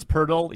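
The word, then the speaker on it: Poeltl: Like literally, I mean, you got Keldon Johnson, Jeremy Sochin, you got Jacob, was that Poti? Or I don't even know how you say his name. – Purtle. I swear to you, it's Purtle, even though there's no R.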